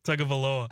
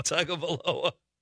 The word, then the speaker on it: Tagovailoa: Tagovailoa. – I don't think Chad said it right. – Tagovailoa. – Tagovailoa.